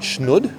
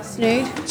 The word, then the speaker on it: snood: Am I saying it right? Schnud? – Snood. – Snood. – Like from the Lorax. – Oh, isn't that a snead? – Yeah, that's a snead, but it's a snood.